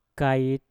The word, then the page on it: kait: Mpur